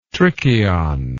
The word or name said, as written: trichion